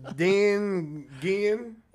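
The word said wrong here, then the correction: Dinuguan: What's den gin? – All right. – That's blood soup. – Oh, okay. – That's not blood. How do you pronounce it? – It's Diniguan. – Diniguan.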